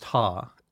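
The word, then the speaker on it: ha (ห้า): The five is pronounced ha in Thai.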